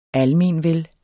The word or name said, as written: ALMENVEL